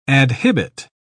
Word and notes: adhibit: British English

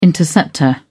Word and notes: interceptor: British English

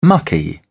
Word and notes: mucky: British English

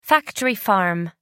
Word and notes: factory farm: American English

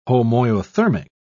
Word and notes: homoiothermic: British English

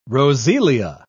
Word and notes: roseola: British English

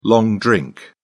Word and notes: long drink: British English

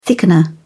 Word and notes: thickener: British English

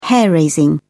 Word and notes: hair raising: British English

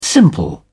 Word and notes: simple: British English

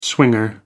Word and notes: swinger: American English

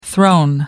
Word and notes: thrown: American English

